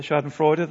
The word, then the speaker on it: Schadenfreude: Is it Schadenfreude? How do you say it? Schadenfreude, that's it.